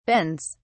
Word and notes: bends: British English

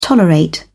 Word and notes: tolerate: British English

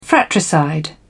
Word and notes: fratricide: British English

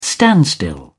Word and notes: standstill: British English

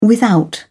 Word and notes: without: British English

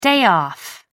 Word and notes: day off: American English

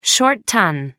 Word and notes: short ton: American English

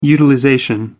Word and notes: utilization: American English

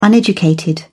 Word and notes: uneducated: British English

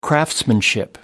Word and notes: craftsmanship: American English

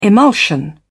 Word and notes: emulsion: British English

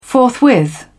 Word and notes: forthwith: British English

